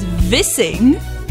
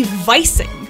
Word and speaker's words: Vissing: Vissing, kissing, all spelled the same way. But without fail, every time someone reads my last name, they call me Vising.